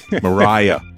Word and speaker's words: Maria: Mariah. – Yeah, it's spelled Maria. – But we say it Mariah.